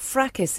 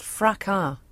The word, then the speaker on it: fracas: It's not fracas, it's pronounced fracas. – Well, there's an S at the end of it. – Yeah, I know, but you don't pronounce a fracas.